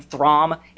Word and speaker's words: Throm: I think it was Throm is how you say her husband's name, Throm. Yeah, it's Throm, T-H-R-O-M.